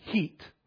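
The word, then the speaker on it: Hit: It's in Iraq, and it is the city called today Heat. They spell it H-I-T, I guess, in English, but it's pronounced Heat.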